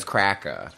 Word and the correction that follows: Krakow: How do you spell it? Is it K-O-W? – I think it's just I think so. – Okay. Krakow. – It's just Krakow. Well, I've been saying it wrong this whole time.